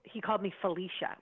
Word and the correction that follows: Felicia: — with Felicia Rose. It's pronounced Felissa.